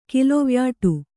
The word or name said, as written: ಕಿಲೊವ್ಯಾಟು